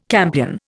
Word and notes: campion: Indian

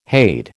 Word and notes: hade: American English

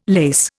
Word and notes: lase: Indian

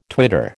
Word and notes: twitter: American English

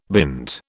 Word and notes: bint: British English

